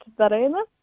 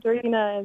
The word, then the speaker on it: Daryna: In Ukrainian, it's pronounced Daryna. – Okay, Daryna. Okay. – Yeah. – Okay. – But Daryna is kind of how I've been.